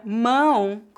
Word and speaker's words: mão: A palavra mão, com pronúncia nasal, refere-se à parte do nosso corpo que se encontra na extremidade dos braços, as mãos. A pronúncia dessa palavra é feita com o ditongo nasal.